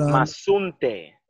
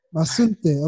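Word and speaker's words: Mazunte: But yeah, speaking of like where you are now, sorry, I can't pronounce the name, but Masunte. – Masunte, okay.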